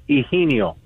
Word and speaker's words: Eugenio: It's actually, it's a Spanish name, my first name is Eugenio.